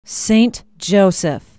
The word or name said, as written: Saint Joseph